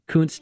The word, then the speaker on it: Kountze: And Kuntz is a little town northeast of Houston. It's spelled K-O-U-N-T-Z-E. Kuntz, Texas, these cheerleaders who have been putting huge Bible verses on the banners that the football players run through.